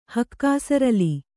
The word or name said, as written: ಹಕ್ಕಾಸರಲಿ